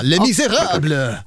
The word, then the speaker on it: Les Misérables: Le Miserable. – Det var fantastiskt uttal. Mycket bra. Le Miserable.